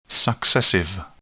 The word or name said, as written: successive